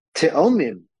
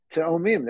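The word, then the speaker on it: Teomim (תאומים): Teomim, with an aleph. Teomim, that's how you say it, not tomim.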